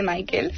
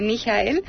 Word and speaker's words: Michael: El primero que voy a decir es Michael Ende, que muchos le conocen por Michael Ende, y no se dice Michael, se dice Michael,